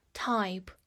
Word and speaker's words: type: type，t y p e，type，这里的字母 y 发 i 这个音，末尾的 e 同样不发音。 type，首先它是一个名词，表示类型或者品种。